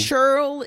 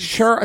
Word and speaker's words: churl: Churl is... – Churl. It sounds like my Aunt Cheryl. How do you spell it? – Let me give you... – Churl. – C-H-U-R-L.